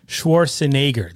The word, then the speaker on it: Schwarzenegger: Schwarzenegger. That's how you say his name. NAY.